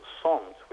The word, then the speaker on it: samt: But for samt, also it's pronounced somt.